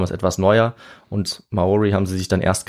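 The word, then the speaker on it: Māori: Man nennt sie auf Deutsch Maori. Ah, ja. Oder in deren ja. Sprache spricht man es ein bisschen anders aus, eher so Maori oder ja. Maori.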